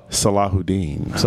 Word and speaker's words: Sallahudin: Sallahudin.